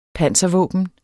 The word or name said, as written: panser